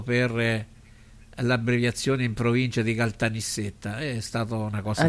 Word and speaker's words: Caltanissetta: A Caltanissetta. – L'hai detto bene? L'ha detto bene? – Caltanissetta. Sì, l'ho detto bene.